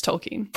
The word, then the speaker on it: Tolkien: It's Tolkien.